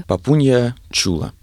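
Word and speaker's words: Папунья Тула: Папунья чула. – Давайте, может быть, об этом поговорим. – Пишется оно, конечно, такое? – Тоже по-разному, но все-таки я настаиваю на произношении как че.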